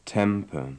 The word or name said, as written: temper